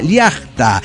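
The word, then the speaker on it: llacta: Bueno, a propósito, Liacta se pronuncia Liacta, no significa valle, significa tierra, campo, nación, lugar.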